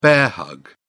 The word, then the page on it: bear-hug: British English